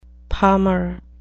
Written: palmer

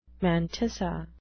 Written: mantissa

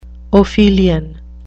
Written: ophelian

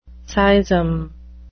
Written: seism

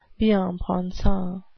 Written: bien-pensant

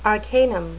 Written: arcanum

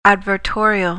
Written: advertorial

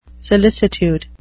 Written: solicitude

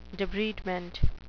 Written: debridement

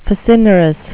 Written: facinorous

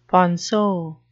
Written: ponceau

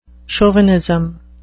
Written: chauvinism

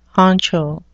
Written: honcho

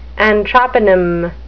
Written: anthroponym

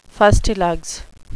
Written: fustilugs

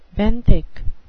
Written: benthic

